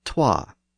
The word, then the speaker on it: twa: So the word so was originally swa, and the number two was originally twa.